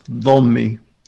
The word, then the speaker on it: dhimmi: That's in Arabic, it's pronounced dhommi, but what it means is you have to, Christians and Jews have to be subservient to Muslims.